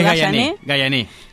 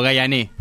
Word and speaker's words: Gayané: Quiero preguntar si lo pronuncié bien. ¿Es Gayané? – Es Gayané. Sí. – Yo tengo un miedo de errarle, por eso siempre lo digo y después pregunto. – No, está bien pronunciado, Gayané.